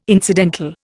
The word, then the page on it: incidental: Indian